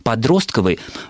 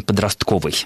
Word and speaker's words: подростковый: Подростковый, да, ударение на второй слог, произнести, скажем так, экономически менее выгодно, чем подростковый.